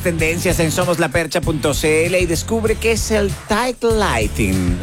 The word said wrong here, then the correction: tight lining: Lining. Tight lining. ¿Lo dije bien? – Sí.